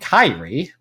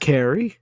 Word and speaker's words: Kairi: the Japanese girl is named Kairi. – Oh, I was close with Carrie.